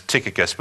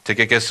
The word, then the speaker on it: Tychicus: So for my entire life, I've always pronounced it, this name is Tychicus. But when I started doing research, all of this modern scholars today pronounce it Tychicus, not Tychicus.